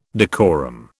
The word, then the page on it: decorum: British English